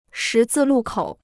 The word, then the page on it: 十字路口: shí zì lù kǒu